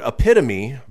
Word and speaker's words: epitome: He said the word epitome, but like it rhymes with home.